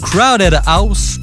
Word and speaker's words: Crowded House: C'est euh, Crowded House. – Je sais pas si je prononce bien. – Ouais, ça se dit bien.